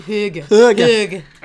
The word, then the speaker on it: hygge: It does give you that hygge. Is that how you say it? – I think hygge. – I haven't heard this. What's this? How's it spelled? – H-Y-G-G-E.